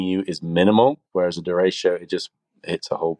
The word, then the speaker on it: derecho: I think I've always pronounced it like Derrico, or like rhyming with Jericho. So pronounce that one for me again. – Derecho.